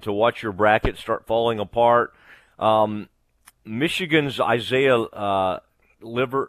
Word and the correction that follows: Livers: how do you say that, Steven? He's a good, really good player. I don't want to say that wrong. Livers, okay. It sounded weird when it was coming out.